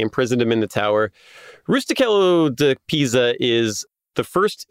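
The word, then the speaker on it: Rustichello: His cellmate in the tower is a dude named Rusticello, which I don't know if the CH is a hard CH or a soft CH. – It's a hard C, yeah. – Okay, Rusticello.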